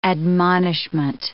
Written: admonishment

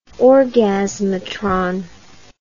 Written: orgasmatron

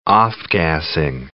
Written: off-gassing

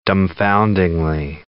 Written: dumbfoundingly

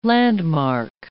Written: landmark

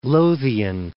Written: East Lothian

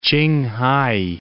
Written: Tsinghai

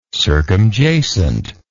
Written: circumjacency

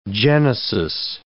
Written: genesis

